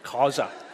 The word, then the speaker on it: Khoisan: How do you say Khoisan? Khoisan. Okay, it's got little clicks in it.